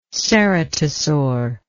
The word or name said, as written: ceratosaur